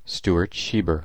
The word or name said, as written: Shieber